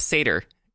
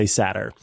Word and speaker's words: satyr: Yes, but we have to pronounce it Satter. – Satter. – It's the only way. And apparently that's the second pronunciation. So you can say Satyr or you can say Satter.